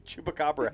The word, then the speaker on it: chupacabra: No, the proper way to say it. Chupacara. – Chupacabra. – Yeah. – Chupacabra is the Americanized way to say it. It's chupacabra.